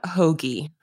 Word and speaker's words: Hogi: It's Francesca Hoagie.